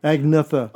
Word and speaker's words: Agnetha: Agatha? – Agnetha. – Oh, Agnetha.